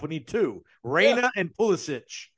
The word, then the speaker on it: Pulisic: Is it Pulisic? – Is that how you pronounce it? – Yeah.